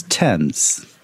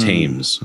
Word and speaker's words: Thames: And then he's like, well, no, that's how it's said. Listen, I'm not just I'm not just pronouncing it like a Newfoundlander. This is, that's how it's said. It's Thames.